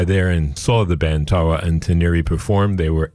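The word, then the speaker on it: Tarwa N-Tiniri: And that's a track called Zaman, and it is the title track of a brand new album out from a Moroccan band, Tarwa Ntaniri. I will spell that for you again. That's T-A-R-W-A, Tarwa, and then N-hyphen T-I-N-I-R-I, Tarwa Ntaniri.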